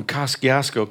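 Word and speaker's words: Kosciusko: So, yeah, and you're from Kosciuszko County, right? – That's right. And good, good pronunciation.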